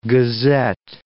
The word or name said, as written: gazette